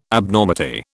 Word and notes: Abnormity: British English

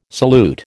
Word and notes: salute: American English

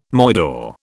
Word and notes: moidore: British English

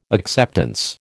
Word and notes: acceptance: American English

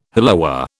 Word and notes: hollowware: British English

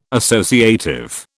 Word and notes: associative: British English

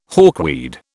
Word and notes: hawkweed: British English

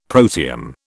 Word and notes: protium: British English

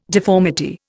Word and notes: deformity: Indian

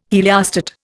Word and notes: heliostat: Indian

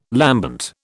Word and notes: lambent: British English

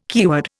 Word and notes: keyword: Indian

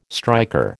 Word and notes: striker: American English

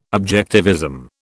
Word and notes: objectivism: British English

